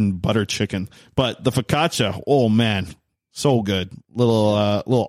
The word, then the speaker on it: focaccia: The focaccia. Right? That's how you say it? – Yeah. – What did I say before? Focaccio? – Focaccia. – Focaccia. That's what I used to say.